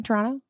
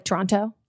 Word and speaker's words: Toronto: And then also you hear how I'm pronouncing it, very Canadian. Toronto, not like Toronto.